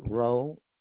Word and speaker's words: Roe: Richard Rowe, R-O-E.